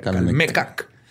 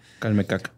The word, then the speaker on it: Calmecac: Es que tiene acento en la de Calmecac. – Calmecac.